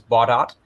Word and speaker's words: Baudot: I learned the pronunciation is Baudot. – I always thought it was French. – It is, but I think just by convention, at least Americans, I talked with C. Grover, who's done a lot of stuff in RTTY and radio things, and he said, yeah, it happens to be pronounced Baudot.